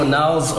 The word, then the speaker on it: Onelz: Like, so Onelz, O-N-E-L-Z, for anyone that's wondering how to pronounce and spell the name. Onelz is Onelz Abo.